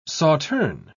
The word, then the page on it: sauterne: British English